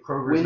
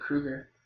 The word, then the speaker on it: Kroger: Although it bothers me that Kroger's Winn-Dixie. – Not Kroger's. Like, it should be Kroger's, but it's just Kroger. Anyway. – I always pronounced it Kruger. – You're wrong. – It's like K-O-R-G-U-R-E-S. – No, it's K-O-R.